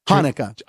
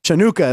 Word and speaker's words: Hanukkah: That's how you pronounce it, right? – Hanukkah. – Oh. – Chinooka is how he pronounced.